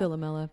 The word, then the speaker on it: Philomela: with these textual analyses of Ovid's myth of Philomela. Is that how you pronounce that? – Philomela.